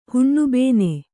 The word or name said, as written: ಹುಣ್ಣುಬೇನೆ